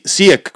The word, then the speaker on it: Siek: Sí, Siek, como se escribe.